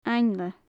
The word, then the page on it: ainle: Gaelic